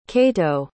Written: kaito

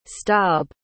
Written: staab

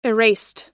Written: erased

